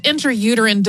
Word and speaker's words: intrauterine: Today, it is a history of the intrauterine device. Intrauterine device is how it's technically pronounced, but it always is going to come out of my mouth, intrauterine, sort of slurred together probably.